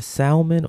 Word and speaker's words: salmon: Is it salmon or salmon? – Salmon. You don't pronounce the L, or people will yell at you.